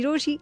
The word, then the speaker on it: Rochie: मेरा नाम है मिर्ची रोशी, नाम की स्पेलिंग है ROCHIE.